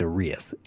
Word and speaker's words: Urias: By the way, it's Urias, accent on the I.